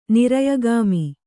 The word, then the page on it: ನಿರಯಗಾಮಿ: Kannada